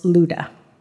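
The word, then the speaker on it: Lydda: Okay, so this is Peter's little trek here from Jerusalem over to, however you pronounce that, in Greek, it's pronounced Luda.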